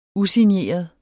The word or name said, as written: usigneret